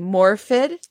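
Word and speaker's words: Morfydd: Is that how you pronounce her name? Is she Welsh? That yes. – must be a Welsh name. Morvid? – Morvid.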